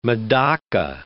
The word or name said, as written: medaka